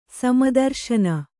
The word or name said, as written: ಸಮದರ್ಶನ